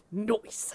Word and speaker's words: Noice: Noyce. Literally, it's spelled that way. – Oh, my God. – N-O-I-C-E, Harold Noyce.